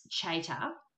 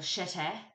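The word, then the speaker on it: Csejte: Pronounce Chater or Chete, maybe. I actually listened to a recording of it. – All right. – To try to get the pronunciation.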